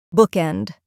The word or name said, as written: bookend